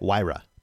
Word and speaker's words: Huayra: Huayra.